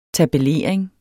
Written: tabellering

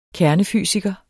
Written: kernefysiker

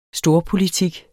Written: storpolitik

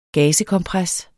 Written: gazekompres

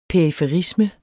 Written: periferisme

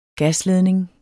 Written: gasledning

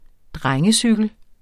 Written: drengecykel

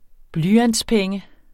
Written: blyantspenge

